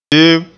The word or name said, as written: deem